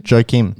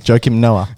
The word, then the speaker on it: Joakim: Silent J. – Oh, what did I say? – Jackin. – Joakim. Joakim Noah. – Joakim Noah. – The J's are H.